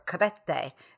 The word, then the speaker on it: Crete: And I was in Chania on Crete, or Crete is the proper way to say it.